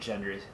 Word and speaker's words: Gendry: you'll definitely see that Gendry, hard G on the Gendry, still rowing meme.